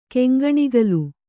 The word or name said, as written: ಕೆಂಗಣಿಗಲು